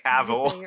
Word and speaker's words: Cavill: Cavill.